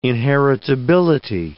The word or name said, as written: inheritability